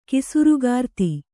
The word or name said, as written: ಕಿಸುರುಗಾರ್ತಿ